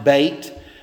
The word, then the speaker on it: Beth: If you look at your Bible above verse 9, you might have a little weird little emblem with the word Beth beside it. That's actually pronounced bait.